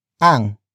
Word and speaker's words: ang: Kanila is spelled as K-A-N-I-L-A. It means they or them in locative form. Next is ang, which is spelled as A-N-G.